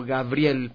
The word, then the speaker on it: Gavriell: Y bueno, pueden encontrarme en Facebook, YouTube, buscando como Gabriel, pero se escribe con B pequeña. Y doble L al final.